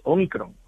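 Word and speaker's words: ómicron: Porque al principio, bueno, sí que es cierto que es Omicron. Uh-huh. Se pronuncia Omicron con a palabra esdrújula.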